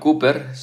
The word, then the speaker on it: Cowper: William Cooper, se escribe su apellido C-O-W-P-E-R, William Cooper fue un escritor inglés de más de 60 himnos.